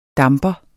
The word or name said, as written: damper